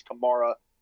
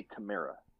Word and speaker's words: Kamara: It's not Kamara, it's Kamara.